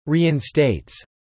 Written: reinstates

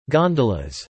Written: gondolas

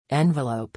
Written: envelope

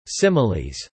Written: similes

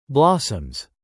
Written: blossoms

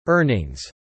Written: earnings

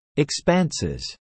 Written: expanses

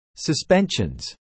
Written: suspensions

